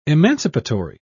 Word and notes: emancipatory: British English

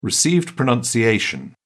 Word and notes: received pronunciation: British English

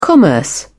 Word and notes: commerce: British English

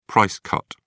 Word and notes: price cut: British English

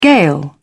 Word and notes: gale: British English

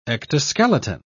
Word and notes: ectoskeleton: British English

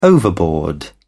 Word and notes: overboard: British English

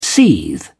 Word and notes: seethe: British English

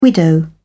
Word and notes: widow: British English

